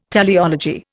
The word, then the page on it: teleology: Indian